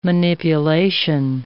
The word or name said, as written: manipulation